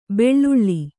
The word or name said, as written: ಬೆಳ್ಳುಳ್ಳಿ